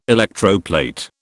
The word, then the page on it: electroplate: British English